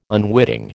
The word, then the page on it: unwitting: American English